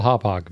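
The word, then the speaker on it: Hauppauge: That random city in New York. Yeah, it's called Hophog, people.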